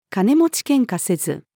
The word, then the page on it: 金持ち喧嘩せず: female